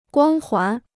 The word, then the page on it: 光环: guāng huán